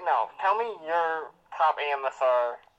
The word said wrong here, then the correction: ASMR: ASMR. – I'm trying to say it right, but I'm saying it too fast.